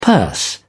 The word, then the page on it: purse: British English